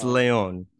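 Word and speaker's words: Lyon: You just say Alex Leon. – I said, it's not lying. Like, I'm trying to get the pronunciation correct here.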